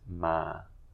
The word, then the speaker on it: ma: Next word is ma. With a mid-tone, which means to come. – To come. And if you pronounce it incorrectly? – So if you say ma, it means horse. If you say ma, it means dog. – Right. Ma, horse, with high tone. Ma and ma, dog.